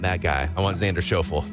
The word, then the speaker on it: Schauffele: Schauffele, right? – Schauffele, yeah. I don't know him. – I've just occasionally watched golf, okay. And so I've heard that's how it's pronounced.